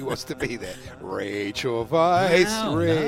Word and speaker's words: Weisz: Rachel, the fine actress, Rachel Weisz.